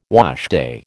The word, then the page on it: washday: American English